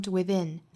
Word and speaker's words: within: By appearances, for beauty is found within. For beauty is found within Beauty 和 is 的连读跟前面这个 by appearances 的连读是一样的，都是要加 y 的发音。 Beauty is found within。 within 这个词的重音在后面这个音节，所以我们读的时候要注意是 da within found within da。